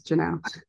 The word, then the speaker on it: Jeanelle: It's Jeanelle.